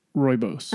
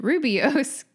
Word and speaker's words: Rooibos: I was thinking about at the tea shop when one of the teas I had ordered, he kept saying, Rooibos. – Uh-huh. And you and I have always pronounced it Rubios because that's kind of how it looks like it's spelled, right? – To be fair, I've never heard.